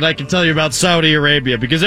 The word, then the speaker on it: Saudi: It's time to learn about Saudi Arabia. I think that's how you say it. Saudi. – Saudi, yes.